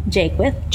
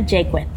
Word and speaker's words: Jaqueth: Jaqueth. – Jaqueth, screwed that up too, but that's what it's about. So, all right, so... Say your name one more time. – Selenia Jaqueth.